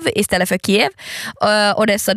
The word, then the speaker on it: Odessa: Odessa. Betoning på andra stavelsen. Så Odessa.